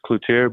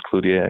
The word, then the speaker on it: Cloutier: And he said, look, we gotta, it can't be Cloutier. It's got to be the French way. It sounds more exotic and it sounds more soccer appropriate. So it's funny. So he put that into the pronunciation in the media guide as the French way, and it kind of just stuck. – My family, it's Cloutier, but I guess my professional name's Cloutier, I guess.